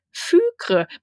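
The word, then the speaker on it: Fykre: Einer meiner absoluten Lieblingscharaktere schreibt sich F-Y-K-R-E. E, Fükre. Man kann es aber auch Fickre lesen.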